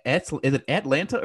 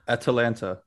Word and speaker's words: Atalanta: Is it Atlanta or Atalanta? – Atalanta.